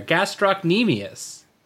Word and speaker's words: gastrocnemius: You said it wrong earlier. It's actually gastropamanius. – It's not, because this is a direct rip from the subtitles from Amazon, which is gastropamanius. No, there's no P in there. Gastrocnemius.